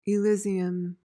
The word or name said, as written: elysium